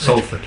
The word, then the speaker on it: Salford: Or is it pronounced Salford? – Salford. Yeah.